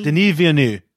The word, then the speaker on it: Denis Villeneuve: How do you say Villeneuve. His name? – Denis Villeneuve.